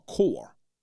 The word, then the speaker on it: corpsman: He didn't have enough sense to know the P and the S is silent and it's just C-O-R, not C-O-R-P-S-E. It's just C-O-R, core, corpsman, not corpsman.